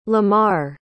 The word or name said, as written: Lamarr